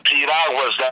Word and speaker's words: piragua: it's called Piraguas. It's like Piragua. That's P-I-R-A-G-U-A. Piragua. It's like, think of pyramid and water. Piragua. – Piragua.